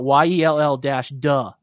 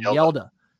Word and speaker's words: Yelda: It's actually spelled out Yell-Duh for how you pronounce that first name. Yelda. – Yeah. – Yelda Froholt. – Like Zelda.